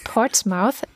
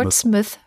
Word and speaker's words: Portsmouth: In der Stadt Portsmouth. Ähm, nee, das Portsmouth. nicht. Portsmouth. Portsmouth, ja. okay.